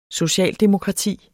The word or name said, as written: socialdemokrati